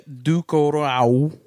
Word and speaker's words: Ducournau: Directed by Julia Ducournau. – Mm-hmm. – Perfect. – You nailed that pronunciation. – Ducournau.